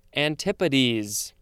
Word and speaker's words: antipodes: And here we have that word, and it looks like it's pronounced antipode, A-N-T-I-P-O-D-E. But how do you pronounce the plural version? It is antipodes. That's how you pronounce it.